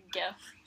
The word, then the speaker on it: gif: It's like a meme or whatever, or like a gif. Which it is pronounced gif, okay. – Not Jif. – Not this Jif.